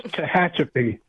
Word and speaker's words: Tehachapi: Let's go and is it Tallahatchie, California? Or get that to me correctly. – Well. – The whole thing wrong. – And the town is much harder to pronounce than that. It's Tehachapi. – Oh, thank you. Thank you, Tehachapi.